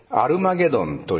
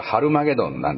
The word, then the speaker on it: ハルマゲドン: 数年前に、だいぶ前になりますけども、アメリカの映画でアルマゲドンという映画がありました。あれはハルマゲドンなんですね。 H の発音があるかないかということなんですけど、あそこでは、隕石がですね、大きな隕石が地球に迫ってきて、その隕石がぶつかってですね、世界が、地球が滅びるんだと。